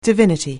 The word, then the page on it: divinity: anglais